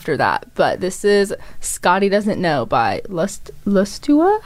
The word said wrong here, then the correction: Lustra: How do you say that? Lustua. – Lustra? – Yeah, Lustra.